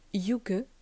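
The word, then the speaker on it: hygge: Euh, ensuite, je peux te proposer aussi, je ne sais pas si tu connais le Yuge, ça s'écrit H-Y-G-G-E. C'est un terme danois et norvégien.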